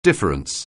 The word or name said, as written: difference